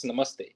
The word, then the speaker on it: namaste: Yeah, it's namaste, which is like hummus, like namaste. Mm. And then the T is flat.